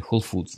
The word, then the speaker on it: Whole Foods: Если будете покупать в Whole Foods, меня поправляли, Whole Foods надо говорить, извините, так называется магазин. И мне сказали, что из-за этого, как я вообще могу преподавать английский, если я не произношу букву S в названии магазина Whole Foods.